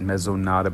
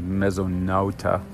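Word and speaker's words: Mesonauta: I always want to pronounce it Mezonata, but I think it's actually Mezonauta.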